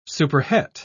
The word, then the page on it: superhet: British English